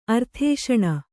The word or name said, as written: ಅರ್ಧಾಸನ